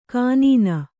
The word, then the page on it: ಕಾನೀನ: kānīna